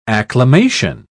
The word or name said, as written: acclimation